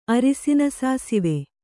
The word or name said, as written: ಅರಸಿನ ಸಾಸಿವೆ